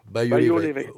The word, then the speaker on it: Bailleul-l'Évêque: Bailleul-l'Évêque.